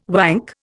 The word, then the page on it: wank: Indian